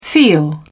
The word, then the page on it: feel: British English